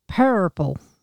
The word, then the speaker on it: Pearlple: The color is called Pearlple. – Yes, but it's not spelled like the color purple. – It's spelled P-E-A-R-L-P-L-E.